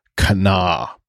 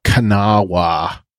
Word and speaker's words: Kanawha: But the proper pronunciation is Kana. It's not Kanawha or Kanawha or however she said it. It is typically two syllables. Well, it's Kana.